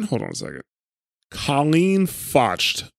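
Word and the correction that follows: Colleen Fotsch: I know I'm saying her name wrong. It's Colleen, Fotsch.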